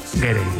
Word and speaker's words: Gere: Estaba pensando yo que si te apellidas Ger, bueno, ¿cómo se dice Ger o Gerre? – Gerre. Me ha hecho gracia porque has dicho Richard Gere y luego el hijo era Gere.